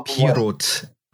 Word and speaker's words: Pieroth: Uh, oh, Pieroth. – Pieroth. Yeah, like the t- the th is like a hmm. is like a t.